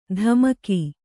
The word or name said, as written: ಧಮಕಿ